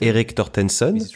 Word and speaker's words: Eric Tortensen: Je suis aujourd'hui avec Eric Tortensen. Je le prononce bien? Oui, c'est encore mieux avec l'accent français.